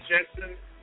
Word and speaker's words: Jensen: Facebook, Brian Jensen, not Jenkins. It's spelled J-E-N-S-E-N.